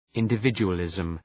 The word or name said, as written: individualism